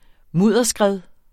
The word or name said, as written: mudderskred